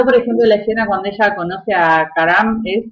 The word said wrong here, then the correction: Kamran: ¿cómo se pronuncia? Kamran.